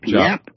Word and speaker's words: Giap: G-I-A-P? How does it pronounce it? Jap? – Jap.